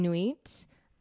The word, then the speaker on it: Nuit: So Nuit. – Mm. – I grew up learning Nuit, which is not coincidentally really, but the French word for night.